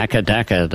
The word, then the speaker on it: AC/DC: Die Aussprache von AC/DC in Australien, die geht so. – Yeah, yeah, akadaka, they say.